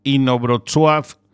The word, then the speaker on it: Inowrocław: concretamente desde Inowrocław, que esto lo he dicho bien, que estuve mirando ahí la transcripción fonética.